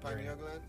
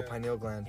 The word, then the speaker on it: pineal: Yeah, pineal gland. – Yeah, there you go, pineal gland.